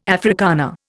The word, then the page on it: Afrikaner: Indian